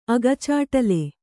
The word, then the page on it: ಅಗಚಾಟ್ಲು: agacāṭlu